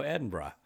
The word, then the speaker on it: Edinburgh: Which Paul Carella told me the correct way to pronounce it is like, he said, you know how you Americans say, hey, brah, what's up, brah? It's that, but with before Edinburgh? – It.